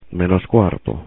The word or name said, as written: menos cuarto